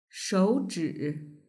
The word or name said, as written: shouzhi